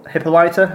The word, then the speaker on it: Hippolyta: One of them is called Hippopolopita. – Hippolyta, I think it is. – We'll go with that.